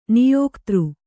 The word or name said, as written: ನಾಯಕಿತಿ